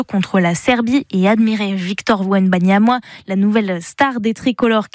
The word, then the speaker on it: Victor Wembanyama: Il s'appelle bien ce basketteur Victor Wembanyama, pardon pour l'erreur de prononciation.